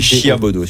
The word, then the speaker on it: Chiabodo: Quand tu écris Chiabodo. – Robert. C-H-I-A-B-O-D. – Non, c'est Chiabodo. – Comme ça se prononce.